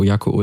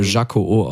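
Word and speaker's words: Jako-O: Jaco-O ist das für Kinder. – Ah, okay. – Ja, ich mag, du hättest so einfach... – Mit dem Vicky sagt, es würde Jaco-O ausgesprochen.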